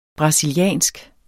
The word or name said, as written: brasiliansk